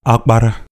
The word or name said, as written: Akpara